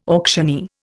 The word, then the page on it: auctioneer: Indian